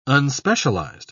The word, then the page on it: unspecialized: British English